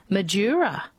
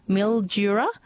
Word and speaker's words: Mildura: Peter from Birdwoodton says mispronounced words, Madura instead of Mildura. I reckon that is one that people probably mispronounce quite a lot. Mildura, not Midura.